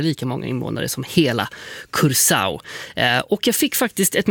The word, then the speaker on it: Curaçao: Då vet ju du hur man uttalar landets namn. – Ja, man säger Curaçao. – Curaçao. – Ja, Curaçao.